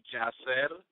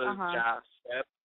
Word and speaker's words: Yasser: Well, yeah, I mean, the name is Arabic, and I guess the Spanish way to pronounce it will be Yasser, as in, Yasser. – Like, the J instead of the Y is pronounced. So Yasser, like, yes, sir, you know.